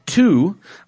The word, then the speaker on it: Too: All right, first we have one from Two, T-O-O, he said to pronounce it that way.